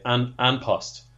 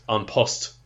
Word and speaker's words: An Post: I'd go for An Post. – It's not that far off. It's, well, I know commentators on the telly kind of say An Post, but it's actually pronounced An Post.